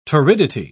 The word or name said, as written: torridity